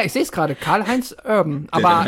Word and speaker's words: Urban: Kannst du mal Fakten checken, wo die Roots von Carl Urban herkommen? – Also ich bin mir sehr sicher, dass er Urban ausgesprochen wird, weil ich das in Interviews und so auch schon gehört habe.